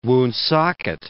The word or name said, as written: Woonsocket